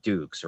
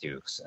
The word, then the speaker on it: Dux: It is pronounced Dukes, right? And I was like, oh, yeah, for sure. It's pronounced Dukes.